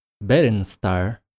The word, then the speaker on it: Berenstar: And the name of this cover, Berenstar, as it would be pronounced in Portuguese, is a portmanteau of Brinstar with an instrument called a berimbau, which is played in capoeira matches.